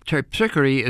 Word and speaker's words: Terpsichore: However, we say, I think, Terpsichore as a different pronunciation here in New Orleans. Terpsichore is the correct pronunciation of the muse of the dance,